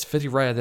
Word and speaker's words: Whitireia: And it's Whitirea that I enrolled in to do this free course. So jump onto their website and have a look. It's Whitirea, which is spelt whitireia.ac.nz. That's the website.